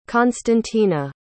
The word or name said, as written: konstantina